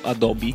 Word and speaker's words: Adobe: Adobe. O Adobe, a seconda di come vuoi pronunciarlo.